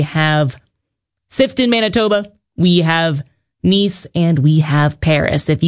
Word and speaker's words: Nice: When I looked it up after, it is spelled nice, but pronounced Nice.